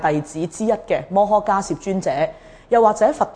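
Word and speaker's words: 摩诃迦叶: 咁迦涉因为佢系一个嘅名称嚟嘅，梵文译过嚟嘅时候，我哋就将呢个字读成个涉字，所以读摩诃迦涉。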